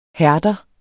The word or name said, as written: hærder